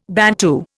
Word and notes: Bantu: Indian

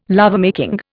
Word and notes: lovemaking: Indian

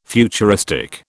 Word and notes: futuristic: British English

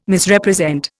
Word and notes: Misrepresent: Indian

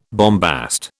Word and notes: bombast: British English

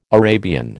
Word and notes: Arabian: American English

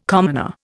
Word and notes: commoner: Indian

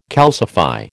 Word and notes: calcify: American English

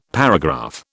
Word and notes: paragraph: British English

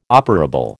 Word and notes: operable: American English

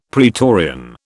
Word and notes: praetorian: British English

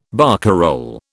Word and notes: barcarole: British English

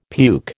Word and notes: puke: American English

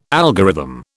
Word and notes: algorithm: British English

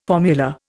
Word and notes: formula: Indian

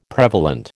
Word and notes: prevalent: American English

